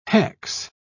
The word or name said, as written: hex